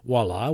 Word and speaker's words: voila: And then when I would read voila, which is pronounced V, or is written V-O-I-L-A, again, it's a French word and English, why is the V pronounced as a W?